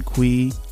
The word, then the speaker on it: Cui: And of course, you could follow me, Brian Kui, last name is spelled C-U-I, on LinkedIn.